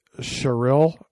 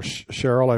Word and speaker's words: Sherrill: If you take Mikey Sherrill, or Cheryl, I've heard it pronounced both ways, she won the New Jersey gubernatorial campaign.